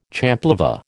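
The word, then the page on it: champlevé: American English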